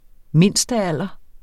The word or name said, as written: mindstealder